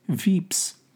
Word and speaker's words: Vips: I caught the bus down to the Vips, pronounced Veeps, in Mexico.